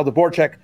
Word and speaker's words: Dvorchak: Also, Kyle Dvorak.